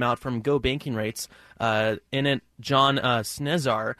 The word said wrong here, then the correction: Cesar: uh, is an uh, oh sorry Cesar. My apologies on the mispronunciation.